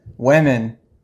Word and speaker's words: women: Women, young girls, the women. Two Margarets, women, I know. – Women. – Francis, women like lemon. – Like lemon, women.